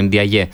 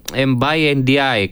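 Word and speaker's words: Mbaye Ndiaye: Και έτσι έχουμε μάθει τώρα να το προφέρουμε Ντιαγιέ. – Να σε ρωτήσω, έχεις δίκιο, ναι, δεν ξέρω, ο ίδιος υπάρχει και ένα βίντεο στην Ευρωλίγκα που οι παίκτες προφέρουν τα όνομά τους και ναι. ο ίδιος λέει Mbai NDI, κάπως ναι, έτσι ναι, ναι.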